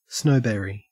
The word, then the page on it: snowberry: Australian English